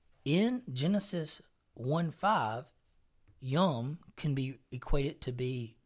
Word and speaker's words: yom: It's Y-O-M. How would you pronounce that? – Y-O-M? – Yeah. – Yum. – Yum. Yum.